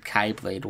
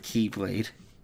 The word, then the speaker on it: Keyblade: I just like how Leonard Nimoy said Kai Blade once, and then they had to put a little footnote and like Dream Drop Distance. That some pronounce it Kai Blade instead of Keyblade.